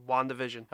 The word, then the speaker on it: WandaVision: Is it pronounced WandaVision or is it like Wanda's Vision? – That's like, because they're both on it. – Yep, WandaVision. – Okay, just making sure.